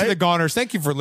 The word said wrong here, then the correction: Gooners: It's pronounced Gooners.